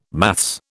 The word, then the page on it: maths: British English